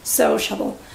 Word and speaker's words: sociable: Otro sería sociable, sociable es una persona sociable, que le gusta estar con otra gente, habla con todo el mundo, sociable. Aquí también cuidadito con la pronunciación. La primera sílaba es no es o, no es una o, es so, sociable, sociable, sociable, sociable.